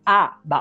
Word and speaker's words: ABBA: ABBA. Ah, obrigado. – ABBA. ABBA. Ah, Os próprios integrantes falam ABBA. Se você perguntar ao Bjorn Leelvos qual é a pronúncia, ela fala ABBA.